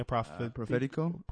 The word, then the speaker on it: Prophetico: How you pronounce it again? – Prophetico. Spell it out for the people, though. – It you have good pronunciation.